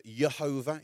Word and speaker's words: Jehovah: This word Jehovah, or Jehovah, if we don't pronounce our J's as they would have done in that time, and some people translated that as similar words like Yahweh, these words were only passed along verbally.